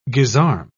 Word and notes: gisarme: British English

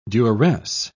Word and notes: duresse: British English

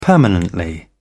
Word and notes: permanently: British English

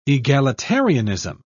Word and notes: egalitarianism: British English

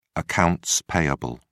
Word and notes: accounts payable: British English

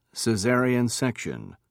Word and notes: cesarean section: British English

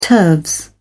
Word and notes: turves: British English

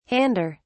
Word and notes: hander: British English

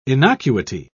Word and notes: innocuity: British English

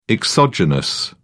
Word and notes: exogenous: British English

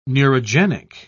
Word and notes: neurogenic: British English

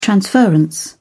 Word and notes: transference: British English

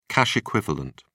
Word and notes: cash equivalent: British English

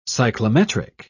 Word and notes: cyclometric: English; British English

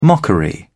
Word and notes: mockery: British English

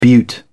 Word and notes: beaut: British English

